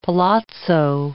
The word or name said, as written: palazzo